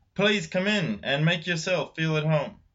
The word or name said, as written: Feel at home